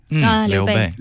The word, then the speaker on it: Liu Bei: Liu Bei? – Liu Bei.